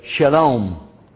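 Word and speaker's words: shalom (שלום): It's the exact same word in Hebrew, only it's pronounced with the sheen instead of the seen. They reverse. There's a sheen and seen in both Hebrew and in Arabic. They say shalom.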